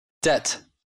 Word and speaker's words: debt: Не читается в сочетаниях BT и MB, если обе буквы входят в один слог. Например, debt, doubt, comb, climb, lamb, dumb.